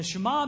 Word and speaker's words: Shema: It's spelled S-H-E-M-A, which is Shema. Okay, now I'm going to say that sometime. Just be ready. But it's pronounced Shema. Say Shema. Shema.